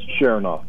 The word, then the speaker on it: Sharonoff: It's pretty close. It's Sharonoff.